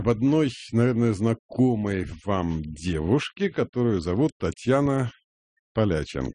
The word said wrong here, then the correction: Поличенко: Поличенко. – И здесь с ударением можно перепутать. – И здесь с ударением, да.